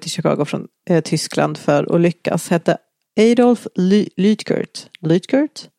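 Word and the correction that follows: Luetgert: Adolf Lutgert. – Adolf Lutgert. – Ah, ja, det har du.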